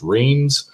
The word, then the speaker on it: Reims: called Reims, or as it's commonly pronounced, Reims.